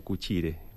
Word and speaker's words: Qscire: Escúchale, lo dije mal, a ver, ¿cómo se pronuncia el nombre? – Tenemos uno de los fundadores que es fanático del latín, así que le gusta llamarlo Cuchire. – Cuchire. – Es un tejillo italiano, es el latín. – Claro, exactamente. – Cuchire se escribe Q-S-C-I-R-E, para los que no los conocen.